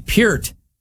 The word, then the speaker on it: Peart: The trailer yesterday dropped for the documentary on late Rush drummer Neil Peart, or as they pronounce it at the beginning, Peart, just like the Trailer Park Boys do.